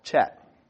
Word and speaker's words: Chet: I'm pretty sure this is pronounced Chet, C-H-E-T-H.